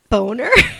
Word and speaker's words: Bohner: Yeah, it's Ralph Boner. – Which, it's not even, it's spelled B-O-H-N-E-R, which to me is Bonner, but.